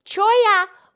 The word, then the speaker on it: Cholla: Yes, Choya was her one and only horse. And Choya is a name of a cactus. And the Choya cactus is spelled C-H-O-L-L-A.